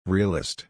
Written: realist

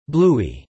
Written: Bluey

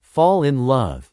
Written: fall in love

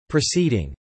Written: proceeding

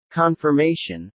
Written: confirmation

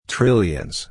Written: trillions

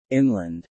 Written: inland

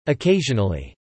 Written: occasionally

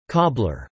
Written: cobbler

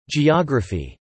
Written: geography

